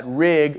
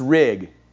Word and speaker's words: Rigg: Rigg rhymes with pig. So I very quickly became Rigg Pig. And then, because people cannot accept that my name does not have an S on the end, it's Rigg, not Riggs.